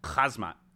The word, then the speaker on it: Khamzat: Khamzat.